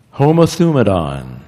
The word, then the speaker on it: homothumadon: And that word is homothumadon. Homo, homogeneous, so that's the first four letters, homothumadon, homothumadon, that's homothumadon, five syllables.